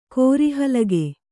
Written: ಕೋರಿಹಲಗೆ

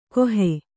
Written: ಕಾಹಿ

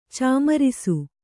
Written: ಚಾಮರಿಸು

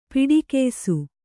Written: ಪಡಿಗಯಿಸು

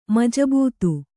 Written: ಮಜಬೂತು

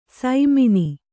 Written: ಸೈಮಿನಿ

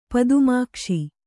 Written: ಪದುಮಾಕ್ಷ

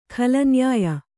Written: ಖಳನ್ಯಾಯ

